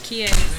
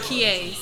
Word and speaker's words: Qiayz: Kie. – What is it? – Kie.